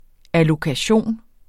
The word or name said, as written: ALLOKATIONERS